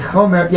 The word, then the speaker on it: chomer: Did I say it correctly? A chomer, yes.